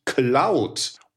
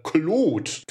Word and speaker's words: Claude: Aber heute geht es uns um die KI von Anthropic, nämlich Cloud oder Claude, kann man je nach Gusto aussprechen.